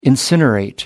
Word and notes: incinerate: American English

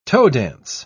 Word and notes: toe-dance: British English

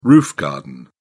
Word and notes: roof garden: British English

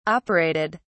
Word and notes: operated: British English

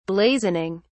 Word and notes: blazoning: British English

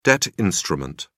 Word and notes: debt instrument: British English

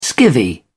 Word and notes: skivvy: British English

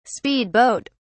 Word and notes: speed boat: British English

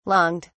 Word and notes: longed: British English

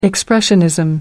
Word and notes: expressionism: British English

